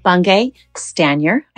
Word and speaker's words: Bungay Stanier: My guest today is Michael Bungay Stanier.